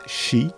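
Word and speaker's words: shi (四): Pour les japonais, le nombre 4 est hanté car il se prononce « shi ». Et ce dernier mot signifie la mort.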